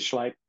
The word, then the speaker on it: Schleip: Now other names come in, like Robert Schleip or Schleip. I can never pronounce the doctor's name. It is Schleip.